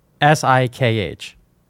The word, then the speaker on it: Sikh: Spell it. Or I K H. S I K H. Sikh.